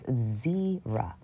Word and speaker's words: zera: Somebody, if you need the help, it's Hebrew word 22:33, and it's zera, not zero. It's spelled like zero, but with an A at the end, zera, okay? Or zebra without a B, zera.